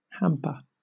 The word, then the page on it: hamper: British English